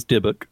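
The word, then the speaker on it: Dybbuk: Two things. One, it is Dibbuk. – Yes, and two, I completely screwed up the pronunciation.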